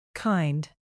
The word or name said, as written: kind